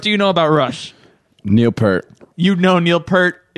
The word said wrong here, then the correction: Peart: You love that guy, even though you can't say his name right. Okay, what? Uh, how do how do you say his name right? How how do you pronounce that correctly? Uh, you it's Neil Peart.